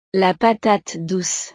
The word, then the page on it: la patate douce: frances